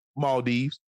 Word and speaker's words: Maldives: Maldives.